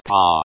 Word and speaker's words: По: Along, through, by, pronounced па.